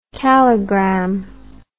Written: calligram

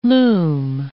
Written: loom

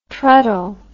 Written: treddle

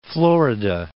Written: Florida Island

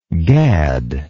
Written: gaddingly

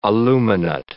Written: aluminate

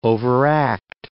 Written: overact

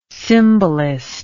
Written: symbolist

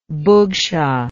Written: bugsha

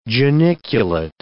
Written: geniculate

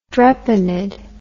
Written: drepanid

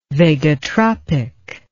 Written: vagotropic

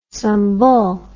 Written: sumbul

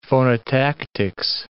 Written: phonotactics

